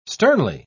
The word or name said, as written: sternly